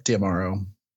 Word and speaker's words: D'Amaro: Well, they started the new year, and is it Josh D'Amaro? Am I saying that right? – Yep, D'Amaro.